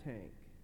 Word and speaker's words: Tank: His name was Pastor Tank. Yes, just like the army weapon, the tank, this is exactly how it's pronounced. That's exactly what it would be in Romanian even.